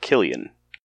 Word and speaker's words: Cillian: It's a Cillian. – And it's actually pronounced Killian.